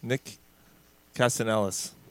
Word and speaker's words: Castellanos: How do we say his name? – Castellanos. – Like Nick Castellanos. – Castellanos. – Castellanos, sorry. – Castellanos. – I think it's Castellanos. – Okay. – He said the L's are silent. – A silent L.